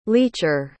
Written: leacher

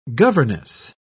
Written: governess

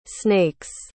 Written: snakes

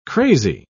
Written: crazy

